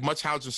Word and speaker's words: Munchausen: I'm saying that wrong, right? – Um, right. And before I even like preface, sir, I'm not gonna try it. – Yeah, it's like Munchausen syndrome by proxy, right? – Mm-hmm.